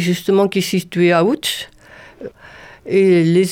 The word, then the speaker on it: Łódź: Il y a eu des échanges avec une académie supérieure de Łódź, c'est Łódź en français, oui. Ça se prononce Łódź en polonais.